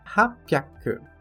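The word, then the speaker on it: hapyak (八百): E no 800, que é hapyak.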